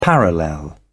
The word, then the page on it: parallel: British English